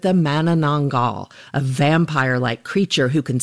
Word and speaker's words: Manananggal: And I gotta say, your pronunciation of Manananggal is quite impressive.